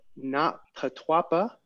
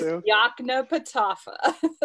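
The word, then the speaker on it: Yoknapatawpha: So welcome to the show. – Thank you. And it is Yaknapatawpha.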